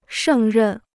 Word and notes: 胜任: shèng rèn

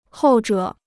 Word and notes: 后者: hòu zhě

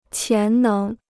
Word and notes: 潜能: qián néng

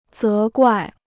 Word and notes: 责怪: zé guài

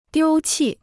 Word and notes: 丢弃: diū qì

